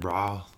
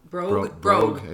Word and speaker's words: brogue: Am I saying it right? Bra? – Brogue.